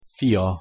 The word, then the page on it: fia: Hungarian